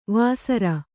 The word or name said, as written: ವೇಸರ